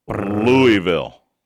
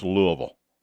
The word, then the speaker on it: Louisville: Teams expected to be favored in 12 games are Alabama, Georgia, Michigan, Washington, and, drum roll, Louisville. – What? – Yes. It blew my mind. – You had to have said that wrong. – It blew my mind. I did. It's Louisville. It's Louisville. Louisville.